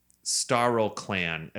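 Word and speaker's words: StarlClan: I think I said Star Chan's name wrong. It's Starl Clan.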